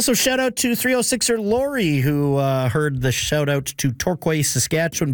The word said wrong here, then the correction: Torquay: but also advised that I'm pronouncing the English version wrong. It's Torquay.